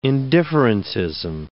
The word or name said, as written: indifferentism